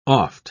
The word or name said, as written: oft